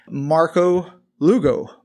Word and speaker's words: Marco Lugo: I have my very first special guest, Marco Lugo. Marco, welcome to the podcast. – Thank you. I feel honored. – All right. And I pronounced all of that correctly, right? – You did.